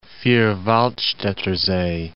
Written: Vierwaldstatter See

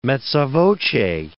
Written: mezza voce

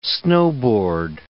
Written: snowboard

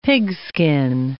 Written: pigskin

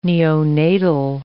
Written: neonatal

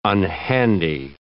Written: unhandy

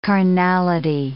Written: carnality

